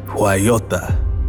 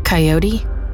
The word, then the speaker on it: Coyota: Not Coyote with a weird accent, but another name altogether.